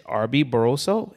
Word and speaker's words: Arby Barroso: His name is Arby Barroso, if I'm pronouncing it correctly. – You hit it right on the nose.